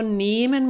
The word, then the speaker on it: nehmen: Und Achtung, also nehmen mit einem Dehnungs-H, deswegen wird es auch lang ausgesprochen.